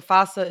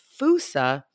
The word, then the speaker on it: fossa: The fossa is the pronunciation in the US and the UK. However, the fusa is how it's pronounced on Madagascar. And so if you for anybody who works over there doing research and conservation, all the experts, a lot of them will use the terminology fusa.